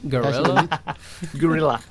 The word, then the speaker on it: Guerrilla: Un juego hecho por Guerrilla Games, ¿lo he dicho bien? – Guerrilla.